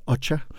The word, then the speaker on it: Okja: Men det er den der amerikansk-sydkoreanske film, der hedder Ocha, eller det staves Okja, men udtales Ocha.